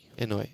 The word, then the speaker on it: Inoue: And now, how do you say that? – Inoue. – Is it an innie or an outie? – I don't know.